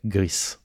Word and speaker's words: Gris: Et c'est un jeu espagnol je crois qui s'appelle Gris. – Gris, qui se prononce ainsi parce que c'est un jeu espagnol, est sorti le 13 décembre, et donc c'est un jeu du studio Nomada, publié par Devolver.